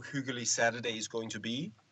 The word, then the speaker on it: hyggelig: But actually, you're going to have to pronounce it hyggelig.